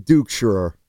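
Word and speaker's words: Duchscherer: I'm going to say Justin Dukeschirer. – That's great pronunciation.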